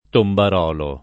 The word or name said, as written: tombarolo